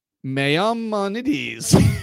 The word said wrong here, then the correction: Maimonides: What? Where is it? – Right here. – Maimonides.